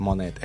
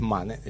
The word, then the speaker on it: Manet: No es Monet, es Manet. Y vos, no sé de qué estás hablando.